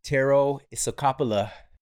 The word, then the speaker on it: Tero Isokauppila: Let's jump into this conversation with the one and only Tero Isokauppila. All right, we've got Tero Isokauppila in the studio.